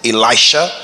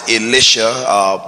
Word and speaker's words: Elisha: Some people pronounce it Elisha. I'll pronounce it Elisha because that's how our church in India pronounces it. And I'll pronounce it Elisha for the sake of separating Elijah and Elisha.